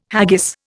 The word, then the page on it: haggis: Indian